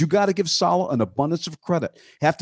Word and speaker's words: Saleh: Now, the Detroit media maven Dennis Dale McClain had mentioned Robert Saleh here Monday as a candidate for the latest head coach of the FLs. But of course, Denny, as this is what butchered the name, listen up, it's Saleh.